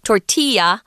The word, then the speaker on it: tortilla: And when you see that word tortilla, and it's not pronounced tortilla. It's Mexican. It's a Spanish word, so it's tortilla.